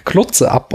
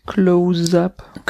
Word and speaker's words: close-up: Auf einer Trage liegend fragte die arbeitssüchtige Schauspielerin von Sternberg, ob er noch ein Close-up, äh, Klotze-up oder Close-up, ich weiß nicht, wie sie es betont hat, Close-up. – Noch ein Close-up äh, bräuchte. Er ignorierte ihre Erschöpfung und korrigierte sofort ihre Aussprache.